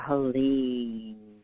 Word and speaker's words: Haleem: H-A-L-E-E-M is the way it's pronounced.